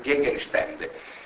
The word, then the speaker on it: Gegenstände: ma a volte modificando la vocale tematica, quindi da A diventa E, una con le si pronunciare una E, toccare una E, quel Gegenstände,